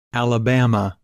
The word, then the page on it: Alabama: English